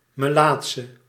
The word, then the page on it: lépreux: [le.pʁø]; /le.pʁø/